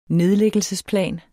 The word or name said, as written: nedlæggelsesplan